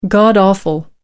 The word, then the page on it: god awful: American English